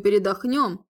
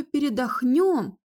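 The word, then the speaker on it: передохнём: Я думаю, передохнем, а дальше видно будет. – Главное в слове «передохнем» не сделать ошибку с ударением. А то получится «передохнем», вздохнув, отметила я.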